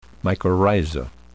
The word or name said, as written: mycorrhiza